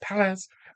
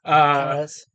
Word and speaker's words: Perez: George Perez, which is how you pronounce his name because I met him once and he said Perez. Perez.